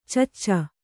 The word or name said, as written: ಚಚ್ಚ